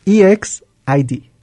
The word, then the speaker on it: EXID: Ok, pues de acuerdo con internet, se pronuncia EXID. O sea, es... – Ninguno ganó. – Es cada letra, ninguno ganó.